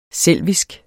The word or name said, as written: selvisk